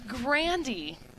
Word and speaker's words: Grande: And I know it's spelled like Grande Burrito, but it's not. – I like how you went with burrito. I honestly expected you to go with coffee. – It's Grandy.